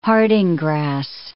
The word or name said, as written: harding grass